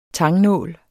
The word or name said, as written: tangnål